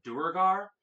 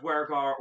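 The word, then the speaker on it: Duergar: A player of mine once brought to our Just real quick, I apologize to anybody else out there that calls it Dwergar. It can be called Dwergar or Dwergar. It is, they're both pronunciations are canonical.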